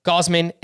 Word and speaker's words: Gausman: Wait, why did I say his name like that? Sandy Gausman and Joe Musgrove. – Yeah, that's the right way to say it.